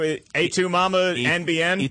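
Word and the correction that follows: Y Tu Mamá También: Etu Mama Tambien.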